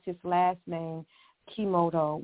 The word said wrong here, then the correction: Emoto: which is Emoto, E-M-O-T-O, and this doctor Masaru Emoto, and he did the experiment about the water.